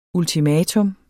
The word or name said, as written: ultimatum